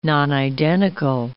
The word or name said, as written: nonidentical